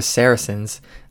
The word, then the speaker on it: Saracens: But moving to Saracens, did I say that right? – Yes, you got it.